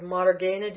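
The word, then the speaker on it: mārgeṇa: Do you see it in the next word, margena? Do you see a long ah there? – Yes, I do.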